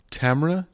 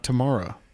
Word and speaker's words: Tamara: By the way, who's pronounced Tamara Jenkins? Not Tamara.